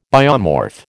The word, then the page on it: biomorph: American English